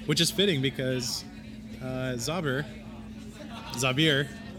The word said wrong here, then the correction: Zauber: Wait, Zauber. – I keep pronouncing it wrong. Zauber is German for magic.